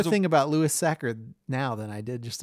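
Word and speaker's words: Sachar: And if you are a teacher, you might want to go there and Sacker rhymes with cracker.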